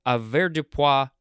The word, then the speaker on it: avoirdupois: It is the word avoirdupois or avoirdupois. Avoirdupois, yes. Or you could emphasize the A. It is the first form of two. It is spelled A-V-O-I-R-D-U-P-O-I-S, and it looks like it is all one word.